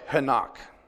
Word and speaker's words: Henoch: Now Enoch. We say Enoch, Enoch, but in the Hebrew it's spelled Henoch.